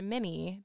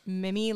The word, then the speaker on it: Mimi: Mimi Paley is Delia. I believe it's pronounced Mimi because her Instagram bio says Mimi like Jimmy.